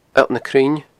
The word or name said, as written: alltnacruinne